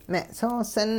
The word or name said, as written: me'soosin